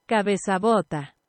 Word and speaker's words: Cabezabota: And the track that we're going to listen to, Metales de Vizcaya, is the name of the track, and Cabezabata. I don't know why I keep saying that incorrectly. – Cabezabota. – There you go.